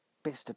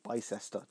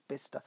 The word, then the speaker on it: Bicester: So, for instance, up in Oxfordshire, there's the town of Bicester, which is pronounced Bicester, but now at times you see it on TV, Bicester. No, it's Bicester.